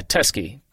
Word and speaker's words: Teskey: Yeah, Teskey, T-E-S-K-E-Y Brothers.